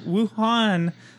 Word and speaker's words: Wuhan: Wuhan lab logo.